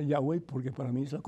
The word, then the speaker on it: Yahweh: ellos argumentan que el verdadero nombre y la forma de pronunciar el nombre de Dios es con el nombre que lo pronunciamos nosotros, que es Y, a-H-W-E-H, que significa Yahweh o significa yo soy el que soy.